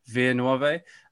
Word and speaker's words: Via Nuove: I don't speak Italian, but I speak Spanish, so I don't know how I pronounce that right, but I'd say Via Nuove. – That feels right.